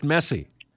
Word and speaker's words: Messi: Either do you, apparently, because the name is pronounced Messi, you know? – Well, exactly.